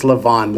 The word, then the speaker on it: Laban: Then Jacob said to Laban, or Laban, pronounce it however you want. In Hebrew it's Laban.